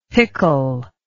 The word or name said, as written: picul